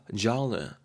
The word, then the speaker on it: genre: genre 유형이나 뜻으로도 되고 장르가 여러분 이게 영어가 아니고 어디에서 왔더라? 지금 정확하게 까먹었는데 프랑스 말이었는가? 하여튼 그래서 발음이 좀 되게 어, 섹시한 반면에 좀 어렵죠. genre 이렇게 되고 있습니다.